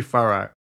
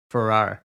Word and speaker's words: Farrar: I'm probably saying it wrong. I think maybe Farrar. Farrar. – Okay, that seems more. – F-A-R-R-A-R.